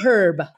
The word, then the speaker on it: herb: I've also adopted pronouncing the letter H in the word herb. I like it. I learned it in Australia. I say herb.